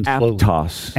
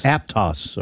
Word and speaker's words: Aptos: What pronounce that again? – Aptos. – Aptos.